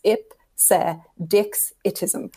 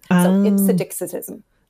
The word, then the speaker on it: ipsedixitism: So it's actually easier to pronounce than it looks. It looks very long on the page, but you break it up into tiny chunks. So it's Ipsedixitism. Ipsedixitism.